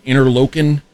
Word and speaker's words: Interlochen: Interlochen.